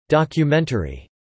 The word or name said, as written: documentary